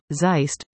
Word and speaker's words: Zeist: The oldest uncle, Zeist, pronounced like, exist, went into the Roman Catholic priesthood.